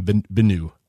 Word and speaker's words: Benu: Benu. Did I do it right? – You did it right.